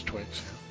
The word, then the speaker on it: Twyxx: To a ranger by the name of, I would say Twix. T-W-Y-X-X. How would you say that? – That's Twix.